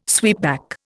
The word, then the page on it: sweepback: Indian